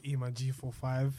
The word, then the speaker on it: E-Man G45: It's E-Man G45, the G solid, my name, the 45 is silent.